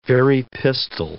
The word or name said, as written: Very pistol